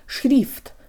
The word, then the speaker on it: шрифт: guys, today you'll learn how to pronounce the word шрифт, or font. Sha-er is tricky, but English has something similar. Shoulda, coulda, woulda. Shoulda, short for should have, sounds like the Russian sha-er combo, and if we practice flicking the tongue when we say it, we get really close.